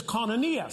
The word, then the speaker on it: koinonia: The word communion in the Greek is koinonia, spelled with a K.